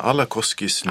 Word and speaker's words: Alakoski: Vilka får läsa Susanna Alakoskis nya bok? Alakoski har vi kallat henne på svenska hela tiden. Hon säger det själv. – Alakoski.